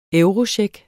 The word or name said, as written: eurocheck